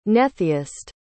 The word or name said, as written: netheist